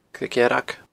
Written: Creag Dhearg

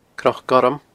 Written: cnocgorm

